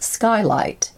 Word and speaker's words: SCY Light: One program called SCY Light, short for Secure and Laser Communication Technology, and pronounced Skylight, is supporting the research and development of optical and quantum technologies for secure and fast data communication from space.